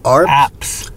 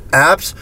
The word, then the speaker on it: Arps: Am I saying that right? Arps? – Apps.